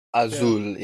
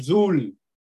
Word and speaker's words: azul: Azul. Is that how you say it? – Azul, yeah, in Spanish. – Azul. – Put a little more accent on it.